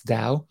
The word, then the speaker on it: Tao: That's called, and it's called Tao, but some it's spelled Tao, but it's Tao.